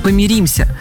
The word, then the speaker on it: помиримся: Думаю, вы будете в шоке, потому что я была шокирована, когда в аудиокниге услышала фразу «мы помиримся». Честно, полезла в словари и обнаружила, что это правда, правильное произношение.